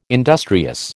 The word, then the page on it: industrious: American English